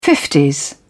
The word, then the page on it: fifties: British English